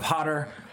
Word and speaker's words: Hodder: And rhyming with Cotter, you have Hodder, Kane Hodder.